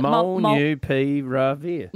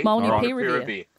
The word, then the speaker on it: Molnupiravir: Oh, is it Molnupiravir? – Molnupiravir? – Mm-hmm. Sounds pretty good to me. – Molnupiravir.